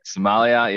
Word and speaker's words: sommelier: And it's loosely based on, and I really hate saying this word, the sommelier. Is it a sommelier? I don't know how to say it. – Somalia. Yeah, I know.